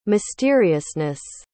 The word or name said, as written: mysteriousness